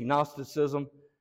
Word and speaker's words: Gnosticism: I mean, you can look up, if you want to study Gnosticism, spelt with a silent G, Gnosticism, or antinomianism.